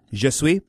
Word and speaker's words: je suis: Je suis.